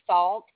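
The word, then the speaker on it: Faulk: It's Falk.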